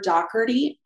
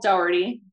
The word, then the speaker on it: Dougherty: I'm the only Briar Dougherty on LinkedIn. Although my last name is spelled Dougherty, D O U G H E R T Y, I'm the only one with that name on LinkedIn.